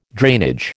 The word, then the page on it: drainage: American English